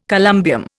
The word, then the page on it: columbium: Indian